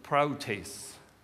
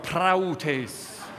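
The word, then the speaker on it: proutes (πραΰτης): The Greek word that's used here and 14 other times in the New Testament is the Greek word proutes. Say it with me, proutes. Now roll your R, praoutes, praoutes.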